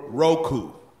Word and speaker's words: Roku: Is it Roku or Roku? – Roku. – R-O-K-U.